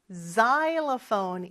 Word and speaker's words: xylophone: 마지막으로 실로폰은 여러분 xylophone 이라고 해요. X로 시작하죠. xylophone 인 거 요거 발음 잘 기억해 주셔야 돼요.